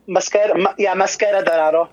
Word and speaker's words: Mascara Dorada: Mascara Dorada, is that right? – Mascara Dorado. Yeah, see, thanks.